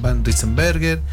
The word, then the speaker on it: Van Rysselberghe: Por ejemplo, hablamos de Pinochet, hablamos de Jaime Guzmán, Patricia Maldonado, Jacqueline Van Rysselberghe, Cass y Lucía Arias.